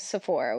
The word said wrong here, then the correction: Zipporah: which is not right. It's Zipporah.